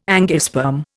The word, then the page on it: angiosperm: Indian